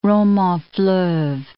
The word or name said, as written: roman-fleuve